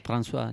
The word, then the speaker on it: François: Hay una canción que se llama Comme d'habitude, de Claude François. Uh-huh. Si no lo he dicho mal, no sé francés. – François, ya.